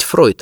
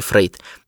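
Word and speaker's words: Freud: Точнее, произносить Фройд, а не Фрейд.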